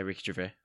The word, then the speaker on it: Gervais: Yeah, Ricky Gervais.